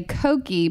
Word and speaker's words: koki: And in Hungary, you say "koki," pronounced like cookie, if you're referring to a small penis.